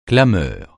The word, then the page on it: clameur: francais